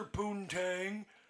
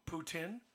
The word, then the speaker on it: poutine: So you ever have someone come up to the window at the truck and say, "Can I have a lobster poontang?" And you're like, "You mean poutine?"